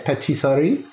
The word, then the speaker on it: Pâtisserie: Is that how you say it? Pâtisserie? Is that the correct pronunciation? – Well, that's very French. But yeah, that's correct.